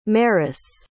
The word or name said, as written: meris